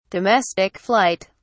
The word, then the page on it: domestic flight: British English